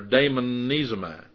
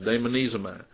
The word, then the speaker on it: daimonizomai: or daimonizomai, I believe is the way it's pronounced. Daimonizomai.